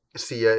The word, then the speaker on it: Sia: Sia is a two-syllable name. Yeah. All right. Yeah. I'll give it to you. – Great. – I'm not even going to try because we're going to get into an argument about how many syllables are in things. And I don't want to do that on this show. – Sia is two syllables.